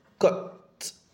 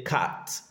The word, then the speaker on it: cut: So let's look at this word, cut. This is cut, cut, cut. You don't pronounce this as ah, so you don't say cat, that will be wrong.